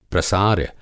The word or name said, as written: प्रसार्य